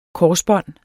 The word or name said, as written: korsbånd